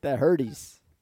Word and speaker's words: Herdez: Herdez. – Yeah, you want to roll the R. I hear you. You want to roll. – It's one R. It's not two.